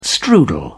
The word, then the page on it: strudel: British English